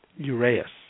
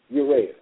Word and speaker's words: Uraeus: How do you pronounce it? – Uraeus. – Uraeus.